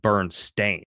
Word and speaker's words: Berenstain: I remember having those books as a kid, and I remember it being B-E-R-N, I guess it was S-T-E-I-N. – So a lot of people remember it being Bernstein, but it's in fact Bernstein. – Bernstein. – And it's spelled B-E-R-E-N-S-T-A-I-N.